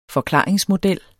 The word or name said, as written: forklaringsmodel